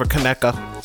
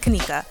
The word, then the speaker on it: Kenneka: Kanika or Kaneka? Mm-hmm. I'm going to say Kanika. I'm probably pronouncing it wrong, but. Most likely Kanika. Kanika. I think Kanika over Kaneka. – Yeah, well, it's spelled, it's spelled K E N N E K A. – Kanika. – Oh my goodness. so her name is Kanika.